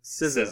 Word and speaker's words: SZA: I thought it was Zaw with a silent S.